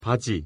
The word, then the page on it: 바지: Korean